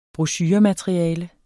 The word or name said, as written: brochuremateriale